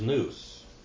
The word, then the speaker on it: nous: Another word for mind in Greek is nous. Nous. N-O-U-S.